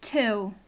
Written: till

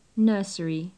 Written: nursery

